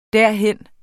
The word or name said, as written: derhen